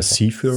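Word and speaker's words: Zephyr: By the Way, The Cypher, Cypher Song, oder wie spricht man das aus? – Ja, Cypher.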